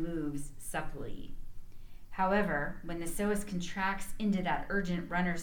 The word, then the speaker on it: psoas: For example, we know that our psoas muscle, and by the way, I'm going to interject here, psoas is spelled P-S-O-A-S.